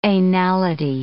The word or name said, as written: anality